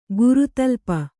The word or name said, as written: ಗುರುತಲ್ಪ